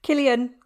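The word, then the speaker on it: Cillian: Killian.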